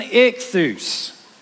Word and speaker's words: ichthus: So, it's pronounced ichthus. It's a transliteration of the Greek word for fish.